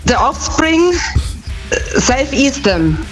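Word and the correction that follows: Self Esteem: Ja, die Aussprache ist ein bisschen... – Steam, he's Steam. – Ja. Ja, ja, ist richtig. – Ja, Self-Esteem nehmen wir auch.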